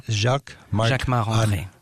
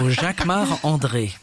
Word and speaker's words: Jacquemart-André: Jacquemart-André. – Jacquemart. – Or no, you say it like André. A Frenchman. – Jacquemart. Of course, because I'm French. So Jacquemart André.